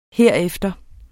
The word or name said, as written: herefter